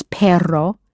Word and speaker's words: perro: If the word for dog is perro, in Spanish spelled P-E-R-R-O, that R, think of a dog growling, that R, how would you say your dog is adorable?